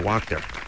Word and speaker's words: Wachter: I'm going to go with Wachter. – You got it. – Yeah. – Rhymes with doctor.